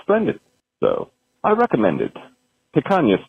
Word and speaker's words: picanha: Picanha.